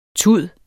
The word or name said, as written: tud